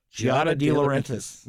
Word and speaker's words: Giada De Laurentiis: Food stars, Bobby Flay, and I don't know how you pronounce her name. Is it Giada? Giada De Laurentiis. Yeah. Well, she's from the movie family and all the stuff.